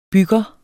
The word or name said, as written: bygger